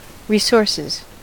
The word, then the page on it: resources: American English